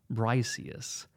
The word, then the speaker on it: Briseis: He then demands that the concubine of Achilles, a girl named Briseis, Briseis.